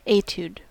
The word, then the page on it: etude: American English